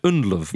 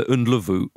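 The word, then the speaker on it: Ndlovu: But we always said Nluv, yes, but we forget the Nluvu. – Yes, I don't know how that happened, but yes, in British commentary, Nluv with the silent U somehow became... Yeah. An established form, and it means elephant.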